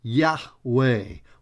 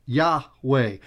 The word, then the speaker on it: Yahweh: Now that is the sacred name of God that we transliterate and pronounce as Yahweh, Y-H-W-H, Yahweh, which is that intimate name that the Jewish faith community relates to God when speaking of God among themselves.